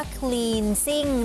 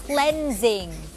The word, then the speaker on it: cleansing: ไม่ได้อ่านออกเสียงว่า cleansing นะคะแต่ว่าอ่านออกเสียงว่า cleansing คำนี้มาจากคำว่า cleanse